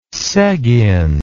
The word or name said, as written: sigyn